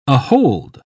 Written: ahold

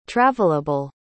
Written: travelable

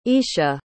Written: isha

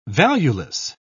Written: valueless

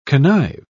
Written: connive

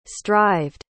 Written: strived

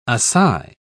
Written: assai

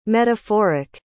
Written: metaphoric

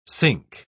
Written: sync

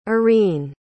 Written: arine

